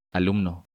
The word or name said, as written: Alumno